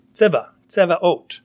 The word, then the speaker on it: Sabaoth: It's spelled with a S-A-B-A-O-T-H. We think of it like Sabbath. Sabaoth, how do you even pronounce that word? It's a Hebrew word, but it means armies. Tzevaot.